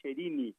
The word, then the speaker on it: Cherini: Só para te ajudar aí, a pronúncia do meu sobrenome é Giovanni Cherini.